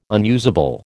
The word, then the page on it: unusable: American English